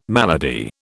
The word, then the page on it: malady: British English